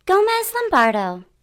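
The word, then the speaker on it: Gómez Lombardo: Gomez Lombardo.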